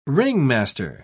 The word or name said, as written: ringmaster